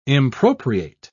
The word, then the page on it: impropriate: British English